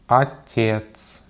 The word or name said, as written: отец